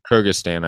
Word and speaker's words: Kyrgyzstan: He's from like Kyrgyzstan or something like that. So, I don't know if you can help with the pronunciation. – Kyrgyzstan, I believe. Kyrgyzstan, yeah.